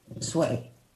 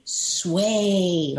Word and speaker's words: Sway: Okay, for years I was saying my name is Kitty Sway. They say, So I made it easy. Kitty Sway, like sway back and forth.